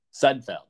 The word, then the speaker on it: Sudfeld: He has a second quarterback, but I'm not counting Nate Sudfeld. – Sudfeld, yep. Rhymes with Budfeld.